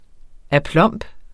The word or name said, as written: aplomb